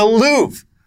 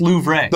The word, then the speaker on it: Louvre: The Louvre, I believe, has been closed for the last couple days. – It's pronounced Louvre.